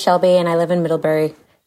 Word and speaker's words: Middlebury: Yeah, it's Middlebury.